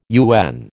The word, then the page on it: UN: American English